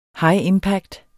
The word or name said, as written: high impact